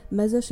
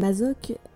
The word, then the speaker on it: Masoch: Alors oui, euh, je vais alterner entre Masoch et Mazoch parce que les deux prononciations sont techniquement correctes, Masoch étant plutôt la prononciation française et Mazoch étant plutôt la prononciation slave et j'ai toujours pas décidé laquelle je préférais.